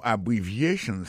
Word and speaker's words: abbreviations: Accent tonique qui vient pour nous prononcer mot yo n'a pour un mot abbreviations nan qui signifie abréviation abbreviations accent tonique là tombé sous troisième syllabe là nan mot ça gain quatre syllabes c'est a bon syllabe bri c'est deuxième syllabe là vie c'est troisième syllabe là chan c'est quatrième syllabe là